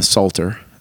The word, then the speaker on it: Psalter: If you don't know, the Psalm 119 is the longest chapter in the Bible, the longest psalm, in the Psalter. As they call it, and it's just spelled with a P. – Yeah, Psalter, the Psalter, and it is subdivided into sections that are named after the different letters of the Hebrew alphabet.